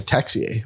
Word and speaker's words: Texier: But the other two on that list are Sean Monahan and Alexander Texier, I believe is how you say his name, right? – Yep.